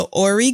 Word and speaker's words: Ori: And have you played either of the Ori games, O-R-I. I might be saying that wrong. – Oh, yeah. No, that's right.